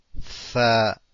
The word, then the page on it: ث: Tha